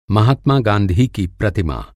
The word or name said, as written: प्रतिमा